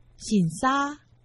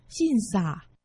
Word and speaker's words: 新社: 在今天节目中介绍客家文化重点发展区台中市新社区，客家话四线腔发音醒沙，海陆腔发音信傻，欢迎听众朋友们一同收听。